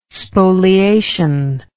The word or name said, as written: spoliation